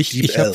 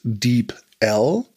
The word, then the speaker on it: DeepL: Ich glaube, DeepL. Ich habe früher immer DeepL gesagt, und irgendwann meinte jemand DeepL. Gut, ja.